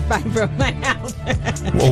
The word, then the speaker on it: Fibromyalgia: Fibromyalgia.